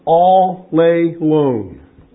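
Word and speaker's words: all lay lone (ἀλλήλων): That word, one another, is actually pronounced all lay lone. All alone.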